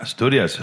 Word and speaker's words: Asturias: You're from Asturias? Am I pronouncing it right? – Yeah, Asturias.